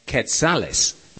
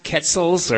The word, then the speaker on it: quetzales: That's quetzales, not quetzals or quetzales or even quensals or quensales, as fresh green gringos and expats often mispronounce it. Even though there's no N in the word, gringos like to slip one in.